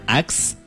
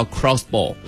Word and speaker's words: Crossbow: 大家可以在网上搜一搜它的照片，是 X-BOW。 – 嗯啊，它叫有人叫它 Xbow，但是准确读音叫 Crossbow。